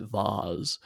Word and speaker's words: Vahz: So during the fight, it turns out that a vase was broken, although it is spelled V-A-H-Z, which is great because then you pronounce it Vaz.